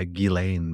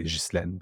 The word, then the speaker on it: Ghislaine: In French, we would say Ghislaine, but Ghislaine Maxwell is what she goes by. – Yeah, I would say Ghislaine as well, but they say Ghislaine. – Yeah, Ghislaine.